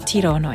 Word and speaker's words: Tirana: Das Hotel ist ideal gelegen, zum Tirana zu entdecken.